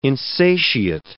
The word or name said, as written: insatiate